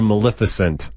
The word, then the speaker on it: Maleficent: It's like magnificent if you didn't have a tongue. Like if you try to say magnificent without a tongue, maleficent.